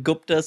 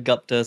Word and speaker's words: Gupta: I grew up, some people said Gupta, some people said Gupta, so 50-50.